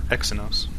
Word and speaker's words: Exynos: Their Exynos. – Exynos? – Yeah.